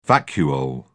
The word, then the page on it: vacuole: British English